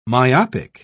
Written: myopic

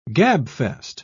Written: gabfest

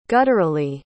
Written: gutturally

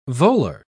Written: volar

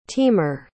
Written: teamer

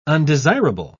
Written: undesirable

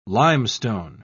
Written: limestone

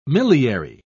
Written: milliary

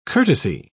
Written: courtesy

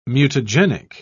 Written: mutagenic

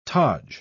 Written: taj

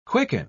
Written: quicken

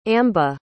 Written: amba

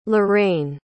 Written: lorain

